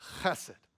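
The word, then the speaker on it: chesed (חסד): That word faithful love in Hebrew, it's chesed. You kind of got to huck up a loogie to say it correctly. Chesed. That's how you know you're doing it right, when you got to spit after you say it.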